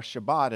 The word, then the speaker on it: Shabbat: The word Sabbath, or Shabbat as it's pronounced in Hebrew, simply means to stop working.